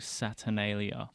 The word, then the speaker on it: Saturnalia: On Tuesday, you are throwing a gig called Saturnalia.